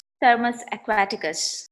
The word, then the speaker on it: Thermus aquaticus: Thermus aquaticus. – So I got it sort of right. – Yeah. – So what exactly is this bacteria and why is it important? – See, this Thermus aquaticus was first isolated from a thermal spring, which means the growth conditions of this bacterium is like high temperature.